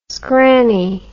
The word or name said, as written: scranny